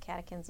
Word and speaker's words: catechins: Catechins.